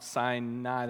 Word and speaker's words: Sinai: Now, Sinai, let's just settle that right now. So that's a little town near here where they mispronounce it. It's Mount Sinai. There's not another I in there. I don't get it. So it's Mount Sinai. That's how I'm going to say it.